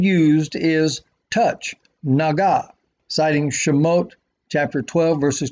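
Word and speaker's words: Naga (נגע): In the Hebrew, the word for touch is spelled exactly the same as plague, Nun, Gimel, Ayin, read right to left. The root is the same. However, by adjusting the vowels, it is pronounced Naga.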